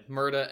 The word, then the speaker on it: Murda: And it is Murda, right? – Yeah, Murda, M U R D A.